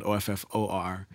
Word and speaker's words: Offor: It's spelled Offor.